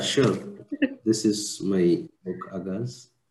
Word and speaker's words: Agaz: And today we have with us who recently launched his book of poetry, Agaz. Did I say it right? – Hello everyone. Hello Anisha. Yes, you have pronounced it right.